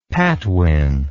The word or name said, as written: Patwin